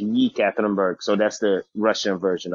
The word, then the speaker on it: Ekaterinburg: So, Coach, how do we even pronounce it? Is it ECAT? – Yeah, so that's where they shorten it up and say ECAT, but it's Ekaterinburg.